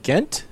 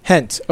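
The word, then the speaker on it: Gent: It's KAA Ghent. Is it Ghent? – Hent.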